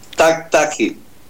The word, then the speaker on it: Tactagi: El apellido es Taktaji. – Taktaji.